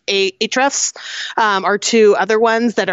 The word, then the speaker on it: Ahrefs: I'm going to botch the name because it's spelled Ahrefs. – Oh, gotcha. – But I think it's pronounced Ahrefs.